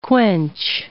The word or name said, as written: quench